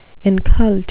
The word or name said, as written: incult